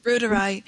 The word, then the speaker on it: reuteri: Reuteri is, did I pronounce it right? – Yeah, Reuteri, Lactobacillus reuteri.